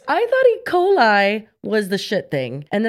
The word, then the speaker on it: E. coli: Oh wait, no, E. cola. – E. cola. – I can't even fucking say it right though when I know what it is. – E. coli.